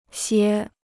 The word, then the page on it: 歇: xiē